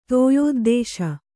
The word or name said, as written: ತೋಯೋದ್ದೇಶ